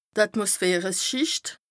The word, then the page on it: Atmosphäreschìcht: Bas Rhin